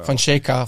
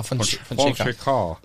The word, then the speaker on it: Fonseca: Ja, der wird anders ausgesprochen wie er. – Fonseca. – Fonseca.